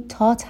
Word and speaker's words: taught: دقت میکنیم که این taught هستش، همون تی که توی فارسی تلفظ میکنیم. Thought نیست.